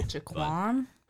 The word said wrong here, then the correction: Joaquin: Do you think you could pronounce his name properly right here on the show? – Joaquin Phoenix. – Oh, my goodness. That's the one.